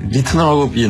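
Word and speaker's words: Ritenour: 원래 그 리나워는 퓨전 재즈인데 네. – 네. – 사실 리트너라고 옛날에 발음했어요.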